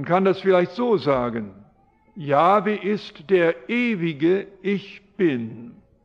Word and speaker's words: Jahwe: Und bis heute hat man keine bessere Aussprache gefunden als Jahwe. Wer ist Jahwe? Früher hat man Jehova gesagt. Das war eine Wortbildung, die auf ein Missverständnis beruhte.